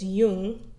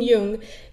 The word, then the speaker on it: Jung: So according to Simply Psychology, Jungian, I know a lot of people say Carl Jung, and I'm used to saying that, but I know in German it's Jung. So I try to correct myself by saying Jung.